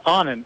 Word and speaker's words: Anand: It's Onan, A-N-A-N-D.